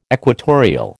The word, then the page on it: equatorial: American English